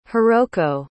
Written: hiroko

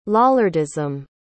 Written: lollardism

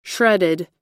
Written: shredded

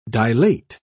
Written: dilate

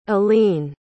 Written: alleyne

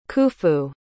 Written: khufu

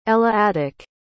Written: eleatic

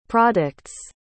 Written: products

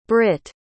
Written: britt

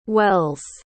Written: wells

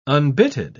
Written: unbitted

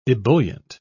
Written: ebullient

